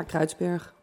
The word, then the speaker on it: Kreuzberg: Ja, je schrijft het alsof je Kruisberg zou moeten zeggen. Ja, ja. en hoe, zeg je, hoe spreek je het zelf uit? Ja, Kruidsberg. Kruis?